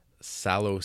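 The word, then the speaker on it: Salò: Sallow sucks. – Is that the correct pronunciation of that thing? – It is.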